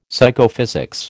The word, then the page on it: Psychophysics: American English